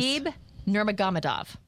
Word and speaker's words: Khabib Nurmagomedov: Khabib Nurmagomedov.